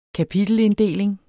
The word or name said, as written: kapitelinddeling